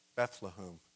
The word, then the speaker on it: Bethlehem: Oh, I said it right, Bethlehem.